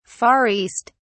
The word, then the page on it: Far East: British English